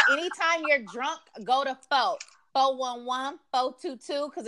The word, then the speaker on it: pho: Is it called pho? – It's pho. It's pho. – It sounds like pho. It looks like pho.